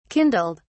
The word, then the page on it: kindled: British English